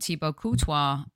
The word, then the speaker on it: Thibaut Courtois: And let me just tell you that when I was on the conference with Carlo Ancelotti and Thibaut Courtois, how'd I do with my pronunciation there, Tom? – That was very good.